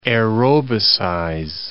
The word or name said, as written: aerobicize